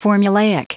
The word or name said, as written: formulaic